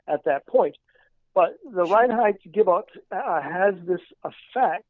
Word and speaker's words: Reinheitsgebot: Another reason says it was to simply keep the citizenry happy and the rabble from uh, revolting was the development of the Reinheitsgebot, which I hope I pronounced correctly. I never know if I do. Sounds great. – Yeah, yeah. Reinheitsgebot.